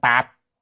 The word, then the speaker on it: táp: Quý vị chu táp chữ đó còn một âm nữa tạp. Nhưng mà âm tạp đọc không có hay, cho nên đọc thường là đọc là chú táp, đọc là táp, táp là vòng.